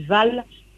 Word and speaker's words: Fall: le, A va toujours se prononcer A, donc vous pouvez dire fal. Moi, je, moi je dis val. Ça c'est une habitude dialectale.